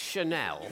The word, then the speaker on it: Chanel: Is it not pronounced Chanel? – Channel. That's so, did I say channel? – You did say yeah.